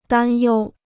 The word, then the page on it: 担忧: dān yōu